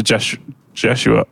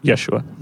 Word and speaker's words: Yeshua: Then the angel of the Lord spoke very solemnly to Jeshua, although I think it's meant to be pronounced Yeshua.